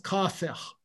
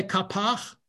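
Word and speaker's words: Kafech (קאפח): It's spelled Kuf Aleph Fe Chet, and it was pronounced Kafech. Okay. A lot of people say Kapach for some reason,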